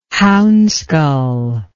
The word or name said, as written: hounskull